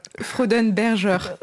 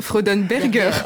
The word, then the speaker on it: Freudenberger: Comment ça se prononce? – Freudenberger. – Ouais, je suis. – Freudenberger.